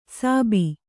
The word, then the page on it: ಸಾಬಿ: sābi